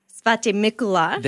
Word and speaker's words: Svatý Mikuláš: Instead it's Svatý Mikuláš.